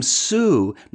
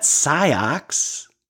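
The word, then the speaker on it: Sioux: He repeated this trial and error several times and finally managed to stutter, "Sue, Sam, Sue," like the woman's name Sue, not Siox.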